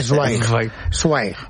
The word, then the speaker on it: Zweig: Por el camino de bien, ahora hay que decir Zweig. – Zweig. – Esteban Zweig. – Es así como se pronuncia en alemán.